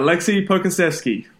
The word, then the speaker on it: Pokuševski: Yeah, Alexei Pokuševski.